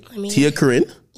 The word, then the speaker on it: TiaCorine: TiaCorine? – Yes.